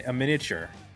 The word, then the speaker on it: aMiniature: And I did mention, Jay, that there is no pronunciate, or you do not pronounce the uh, the A in a miniature. And the reason for that is there was a jazz band called Miniature that already existed, so they added the silent A in order to distinguish themselves from the jazz band.